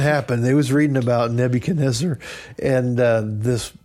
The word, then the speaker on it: Nebuchadnezzar: That's the phonetic pronunciation. The biblical pronunciation is Nebuchadnezzar. Exactly. That's what we're going with. That's what everyone's done.